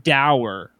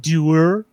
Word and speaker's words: dour: I always said the word dour, like sour, right? Uh-huh. But did you know the English pronounce that word doer?